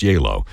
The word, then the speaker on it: Gjeilo: And if you're looking that composer up, his last name is spelled G-J-E-I-L-O, but pronounced Yalo.